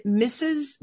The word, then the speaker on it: Mises: He's been a co-founder of three consulting startups and is currently an ambassador for the Mises.